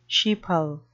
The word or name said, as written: sheeple